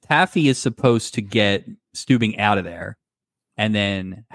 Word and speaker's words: Stubing: I think we're pronouncing his name wrong. His name is Stubing.